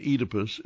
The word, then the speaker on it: Oedipus: The correct pronunciation is a long E, as in Phoenix, Fetus, and Phoebe. The ancient Greek state of Boeotia is pronounced Boeotia. The god of wine is Enus, and Oedipus is pronounced Oedipus.